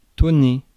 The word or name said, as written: tonner